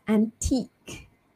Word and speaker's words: antique: Silent U-E, so it's antique, right?